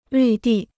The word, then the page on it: 绿地: lǜ dì